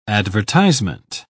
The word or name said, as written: advertisement